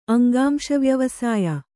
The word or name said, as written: ಅಂಗಾಂಶವ್ಯವಸಾಯ